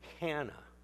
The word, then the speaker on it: Hannah: They would not have pronounced her name Anna. The Greek alphabet does not have an H in it. But when you put a little mark over an A, one way we would pronounce it Anna, but if you put it the other way, it would be Hannah. And that's the way it is in the Greek text. Hannah.